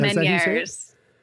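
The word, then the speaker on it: Ménière's: Yeah, who, Meniere's. Yeah, French. I think the guy's French.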